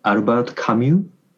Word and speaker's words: Albert Camus: And I also like, I don't know how to pronounce in English, but it's maybe Albert Camus.